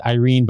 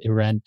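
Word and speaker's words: Irène: I would pronounce it Irene, but in the movie, they pronounce it Irene.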